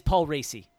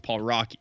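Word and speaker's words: Paul Raci: It is Paul Racy. – It is Paul Racy? Okay, because there was a while there we were going with Paul Rocky.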